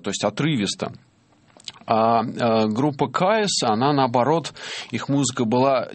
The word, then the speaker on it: Kyuss: Называлась Caius. Вообще сложно, это нужно по буквам диктовать. K-Y-U-S-S. Вот так оно произносится.